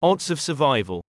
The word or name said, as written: odds of survival